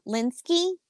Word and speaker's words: Lynskey: And then you have Melanie Lin- oh, Lynskey. Melanie Lynskey. I think I'm saying that right.